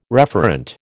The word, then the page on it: referent: American English